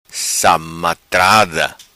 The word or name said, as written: Samatrada